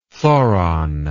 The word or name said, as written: thoron